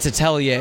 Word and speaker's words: atelier: You're one of the developers, or the developer of, how do you say it, Atelier? – It's Atelier. It's a French word for a like an artist studio.